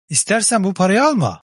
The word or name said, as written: alma